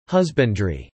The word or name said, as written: husbandry